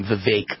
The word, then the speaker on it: Vivek: Vivek Ramaswamy, I think, or actually pronounce his name Vivek.